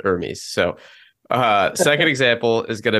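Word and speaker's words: Hermès: It's Hermes, correct? Am I saying that correct? Yep. I should have figured this out before.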